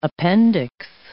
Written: appendix

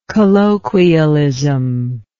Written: colloquialist

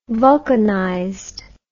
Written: vulcanized

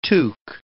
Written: tuque

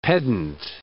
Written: pedant

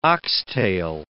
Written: oxtail

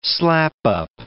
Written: slap-up

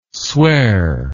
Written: sware

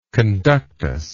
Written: conductus

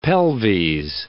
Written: pelves

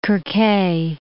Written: Kerkheh

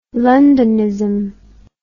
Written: londonism

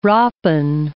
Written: rappen

